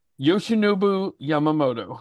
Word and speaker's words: Yoshinobu Yamamoto: I am picking the rookie who actually we could forgive for mispronouncing, Yoshinobu Yamamoto. – And – Yoshinobu Yamamoto.